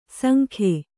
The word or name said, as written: ಸಂಗಿ